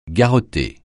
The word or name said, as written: garrotter